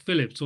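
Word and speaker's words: Phelippes: And Philip, I think he was pronounced Phillips, although it's spelled Philippes, as you say. – Okay. – I think it's Phillips.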